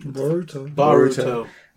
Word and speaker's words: Boruto: And then they have the Naruto Boruto Boruto, how do you say his name? – Boruto.